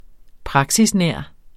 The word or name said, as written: praksisnær